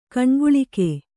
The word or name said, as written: ಕನಕಾಲುಕೆ